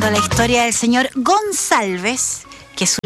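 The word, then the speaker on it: Gonçalves: No es ocupada en Chile, básicamente, pero esto hace que al tener esa cedilla, aquí un autor nos decía, la C se pronuncia como una S suave. Claro, pues, entonces al señor, además de escribirle mal el apellido, le pronunciaban cualquier cosa también, porque él dice, no, si es Gonsalves. Pero bueno, entonces resulta que el caballero a los 77 años dijo, no, ya, no más.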